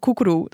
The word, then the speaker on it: koekeroe: Ehm, ja, eerst even koekeroe. Dat spreek ik nu eindelijk ja, goed ja, Dat spreek je heel goed uit.